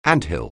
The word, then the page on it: anthill: anglais